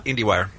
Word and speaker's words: IndieWire: We have Michael Snyder from Inside Wire, who's going to stop by here in San Diego and talk about all the TV shows. Did I say that wrong? – Yeah, huh? IndieWire.